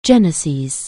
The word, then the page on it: genesis: anglais